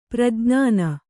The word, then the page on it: ಪ್ರಜ್ಞಾನಿ: prajñāni